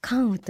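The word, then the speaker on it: 甘雨: 甘い雨と書いて関羽と読みます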